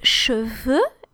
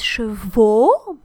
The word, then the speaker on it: cheveux: Still an expression with cheveux, which is not pronounced chevaux, because the O is for horses. Cheveux, like deux, un, deux, trois, quatre.